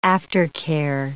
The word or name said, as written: aftercare